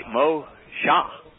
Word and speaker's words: Mosha: M-A-S-H-A-H. So, Mem, Shen, He. The likelihood of it being Moshe is zilch. It's would be Mosha, and it's and you know it's an S-H, not just an S.